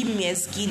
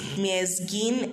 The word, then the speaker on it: Myesgin: Oh, so on Facebook I use Kibapi Myesgin. Kibapi is spelled as K I B A P I while the surname is Myesgin, M Y E S G I N.